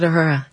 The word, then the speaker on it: Uhura: Lieutenant Uhura.